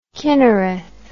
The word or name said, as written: chinnereth